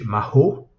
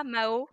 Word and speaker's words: Mahô: Euh, et donc maho, et pas, euh, alors là, là aussi, euh, là malheureusement, on s'est fait un peu avoir, c'est qu'on n'avait pas prévu que certaines personnes le, le, prononceraient mal. Euh, mais parfois. Comme moi, Mao. Bah, du coup, ça fait Mao Zedong.